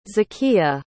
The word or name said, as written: Zakiya